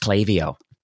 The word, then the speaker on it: Klaviyo: And it's nice to finally, for the record, we know that it actually is pronounced Klaviyo.